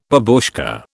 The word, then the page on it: babushka: British English